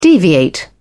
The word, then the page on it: deviate: British English